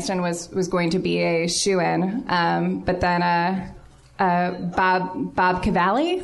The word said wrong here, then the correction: Bobby Cannavale: am I saying that right? – Bobby Cannavale. – Bobby Cannavale from Boardwalk Empire won against Jon Hamm and all the guys from Breaking Bad, and also with Best Supporting Actress?